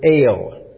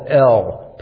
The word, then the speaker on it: El: This term God that we have here in English comes from the Hebrew word El. This is the Hebrew pronunciation. English speakers would call it El, perhaps.